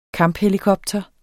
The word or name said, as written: kamphelikopter